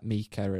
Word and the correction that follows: Micah: and or Micah. I always get his name wrong. – Micah.